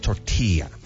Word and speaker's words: tortilla: Someone would stop me immediately, be a white person, and go, it's pronounced tortilla. – And they pronounce it tortilla and pollo. It's tortilla.